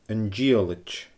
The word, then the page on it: An Dìollaid: Scottish Gaelic